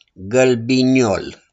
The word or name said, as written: GĂLBINIOL